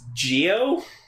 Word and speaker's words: Zi-O: Spelled Z-I-O, apparently. – And apparently it's pronounced Geo? – Because that's the way Japanese language works.